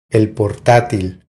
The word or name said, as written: El portatil